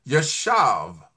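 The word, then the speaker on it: yashav (ישב): It's spelled Y-A-S-H-A-B, but it sounds like yashav.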